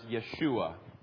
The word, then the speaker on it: Yeshua: In Hebrew, it is pronounced Yeshua.